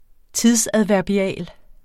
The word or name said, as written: tidsadverbial